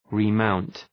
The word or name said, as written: remount